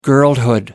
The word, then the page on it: girlhood: American English